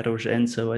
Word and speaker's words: Ruzhentsev: Yeah, Ruzhentsev, I think is the right pronunciation, but you sound confident.